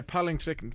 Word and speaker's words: palingtrekken: Yeah, palingtrekken. Very good, mate. That's how it's pronounced.